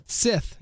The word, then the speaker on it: scythe: Sith, yeah.